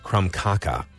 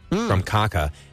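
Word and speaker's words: krumkake: But yes, I completely butchered the pronunciation of this, and it is not German. It is krumkaka, krumkaka, and it's Norwegian.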